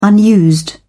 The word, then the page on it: unused: British English